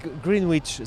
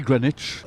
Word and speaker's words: Greenwich: Et bon, c'est la Tour de Londres, l'abbaye de Westminster, le Parlement et Greenwich. – Nous, on dit Greenwich, donc Greenwich. – C'est écrit Greenwich, mais ça se dit Greenwich.